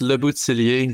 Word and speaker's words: Le Bouthillier: Yeah, it's Le Bouthillier. It's a long French name, and thank you for not destroying it.